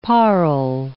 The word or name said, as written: parle